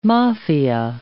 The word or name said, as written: Mafia